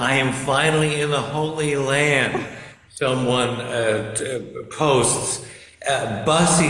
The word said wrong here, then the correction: Buc-ee's: how do you pronounce B-U-C? – Buc-ee's. That's Buc-ee's.